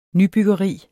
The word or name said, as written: nybyggeri